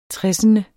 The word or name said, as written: tressende